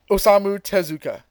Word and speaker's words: Osamu Tezuka: Osamu Tezuka.